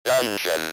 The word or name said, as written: dungeon